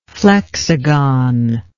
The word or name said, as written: flexagon